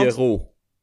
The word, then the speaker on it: Pierrot: Ja, eh, dan, hebben, volgens mij spreek je het uit dus als Pierrot. Ja, Pierrot.